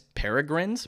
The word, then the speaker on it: Peregrine: Opening this week in theaters, Miss Peregrine's.